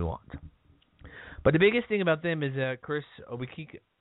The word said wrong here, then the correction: Obekpa: I'm going to butcher the name, Chris Obikike, Obekpa, oh jeez, O-B-E-K-P-A, that guy, Chris Obekpa, is suspended.